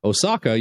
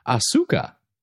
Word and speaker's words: Asuka: Every time you pronounce Osaka, you say it like the Japanese city Osaka. It should be Asuka, but I still love the show.